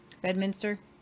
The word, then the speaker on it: Bedminster: How do you say it? Bedminster? – Yes.